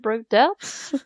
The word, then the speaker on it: Broaddus: Ich hoffe, ich habe das gerade richtig ausgesprochen. Broaddus. Broaddus, gut, ganz, ganz auf der deutschen Art und Weise, sehr gut, an der FU Amsterdam und LMU München.